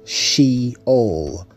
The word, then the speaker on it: Sheol: The word in Hebrew for that is Sheol, Sheol. Now, in English, we spell it as S-H-E-O-L, Sheol.